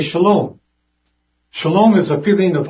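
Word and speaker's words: shalom (שָׁלוֹם): When we look at these four letters, the shin, it pronounces as sh, the lamed is pronounced like an L, the vav is silent, and the M is pronounced like an M. That's where we get the word shalom.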